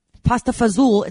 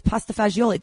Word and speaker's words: pasta fagioli: It's not pasta fazool. It's pasta fagioli. That's the way the Italians say it.